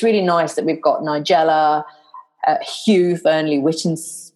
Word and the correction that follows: Whittingstall: I can never say his name, Whittingstall, um, the one who swears, Gordon Ramsay.